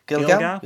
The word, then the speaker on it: Gilgal: Now, we know that that was on in the town, and you pronounced it properly earlier. Gilgal? Is it Gilgal? – Gilgal. Yeah, sounds and good.